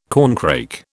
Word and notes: corncrake: British English

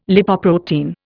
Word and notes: Lipoprotein: Indian

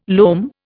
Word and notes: loam: Indian

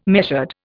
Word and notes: measured: Indian

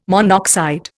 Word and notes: monoxide: Indian

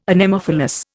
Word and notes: Anemophilous: Indian

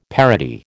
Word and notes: parody: American English

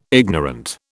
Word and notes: ignorant: British English

